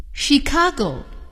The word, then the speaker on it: Chicago: 最后，字母组合 CH 还可以发 SH。我们来听一听。 Machine, a washing machine. SH, chef. He's a chef. Chicago. He lives in Chicago.